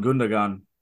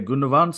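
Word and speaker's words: Gündoğan: I've always gone Gundogan just because it's it's a G. Interesting. but I don't know what the little uh, the little notation above the G is meant to make it. Uh, commentators say Gundogan, so I guess that's it.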